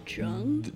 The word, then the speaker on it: Jung: Jung? – The Jung. – Jung? – Yeah. – The Jung. – Spell it? – J-U-N-G.